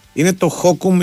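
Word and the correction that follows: Hokum: ή Χοκούμ ή κάτι τέτοιο. – Χόκαμ. Ναι. – Χόκαμ, συγγνώμη.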